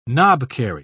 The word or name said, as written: knobkerrie